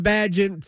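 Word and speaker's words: Bagent: but what have you seen from Badgett since he has taken over for the injured Justin Fields? – So it is pronounced Badgett. – Badgett, my fault.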